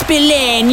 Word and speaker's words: Spillane: It's pronounced Spillane, you muppet.